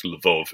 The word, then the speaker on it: Lviv: And annoyingly, this year I was invited to do events in New York, St. Petersburg, Moscow, and Lviv, or apparently it's pronounced Lvov in Ukraine, all cancelled.